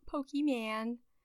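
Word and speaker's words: Pokémon: Actually, it's Pokemon. Not Pokemon. Not Pokemons. – Pokemon. There is an apostrophe over that E, motherfucker, and you'd best pronounce it if you want to get it right.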